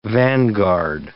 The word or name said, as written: vanguard